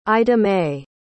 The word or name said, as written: idamae